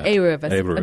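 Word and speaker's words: Aruv (עירוב): Aruv.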